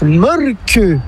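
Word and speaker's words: Mölkky: Inventé en Finlande en 1996, ce jeu de quilles s'écrit M-O-T-R-E-M-A-L-K-K-Y. – Ça s'écrit molky, mais ça se prononce molke. – Le molky. – Plutôt un E qu'un E, mais pas un U. Comme ça fait molke.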